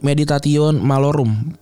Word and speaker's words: meditatio malorum: Para filsuf Stoa melatih diri dengan meditati medi, meditas, meditatio malorum. Sorry gue nggak tahu bacanya. Perenungan. – Ya, bener kok itu kan Latin. – Oke, okay, med, meditatio malorum.